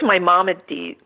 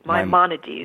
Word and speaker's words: Maimonides: But there was also a book written on this in 1198 by the scholar Maimonides. Am I saying that correctly? – I think it's Maimonides. – Maimonides.